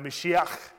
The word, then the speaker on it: Mashiach: Or in Hebrew, I believe it's pronounced Mashiach, right?